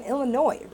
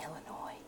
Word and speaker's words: Illinois: You don't pronounce the S. What happened in Illinois?